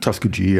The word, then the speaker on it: Tuskegee: Tuskegee, ja.